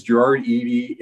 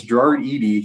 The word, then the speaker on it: Gerard Eady: His name is Gerard Eady, and he is a fucking... Am I pronouncing that correctly? – Yeah, you nailed it. – Okay, cool. Yeah, it's Gerard Eady.